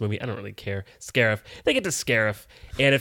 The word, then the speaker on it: Scarif: I mean, at least the very least, the last third when they actually get to Sharif feels very Scarif? Star. Sorry, Scarif. – I mean, Sharif. – No, Scarif. They Scarif. Say it like a bunch of times.